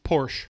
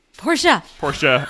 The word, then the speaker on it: Porsche: Porsche. – Porsche. – Oh, she was. I was looking at the glare, and I was like, "What's this glare for?" Yeah, it is a two-syllable word.